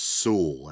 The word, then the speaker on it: Sewell: Back through the magic of editing again, and I did some hunting around, and I believe it is pronounced Sewell, as in, you know, rhymes with jewel.